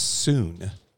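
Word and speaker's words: soon (σύν): But that word together, it in Strong's, it's 4862, and it's pronounced soon.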